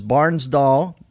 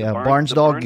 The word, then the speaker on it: Barnsdall: At Hollywood's, listen up, folks, Hollywood's Barnsdall, and maybe I'm mispronouncing that. – No, that's right. – Barnsdall Gallery Theater.